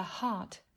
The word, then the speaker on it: heart: Từ này đọc là heart với âm a dài, r và âm cuối là t chứ không phải là hurt hay